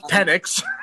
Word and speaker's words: Penix: It absolutely does not. It just shares them. – Yeah, I was going to say they don't rhyme at all. They are just spelled very similarly. – Fine. – Pronounced Penix.